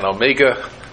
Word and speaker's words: Omega: I looked at an Omega, I'm pronouncing it like James Bond did, an Omega Seamaster.